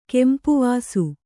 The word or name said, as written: ಕೆಂಪುವಾಸು